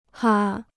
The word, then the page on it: 哈: hǎ